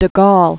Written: de Gaulle

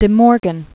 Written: De Morgan, Augustus

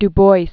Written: Du Bois

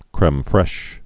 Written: creme fraiche

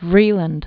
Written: Vreeland, Diana Dalziel

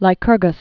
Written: Lycurgus

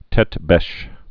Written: tete-beche

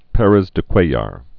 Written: Perez de Cuellar

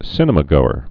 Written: cinemagoer

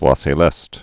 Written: voix celeste

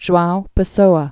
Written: Joao Pessoa

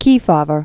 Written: Kefauver

